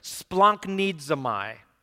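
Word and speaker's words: splanknidzamai (σπλαγχνίζομαι): But here's how you say the word. It's splanknidzamai.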